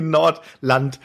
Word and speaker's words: Nordland: Wir wollten eigentlich über die Nordrand. Nord, Nordrand. Schon wieder gesagt. Das gibt's überhaupt nicht. Ich werde das heute kein einziges Mal richtig aussprechen können. die Nordland.